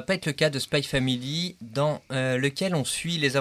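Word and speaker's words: Spy×Family: Alors, je ne sais pas, le X, on prononce ou pas ? Est-ce que c'est Spy Family ou Spy Cross Family ? – Alors, Spy Family, Fred, il va être content.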